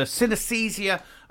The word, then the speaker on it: synesthesia: Because he has, and he's president of the Synesthesia Association. I'm not sure if I'm pronouncing that correctly, but let's talk to him and find out. James Wanaton. Morning, James. – Good morning. Yeah, you're pronouncing that perfectly.